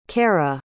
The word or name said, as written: kera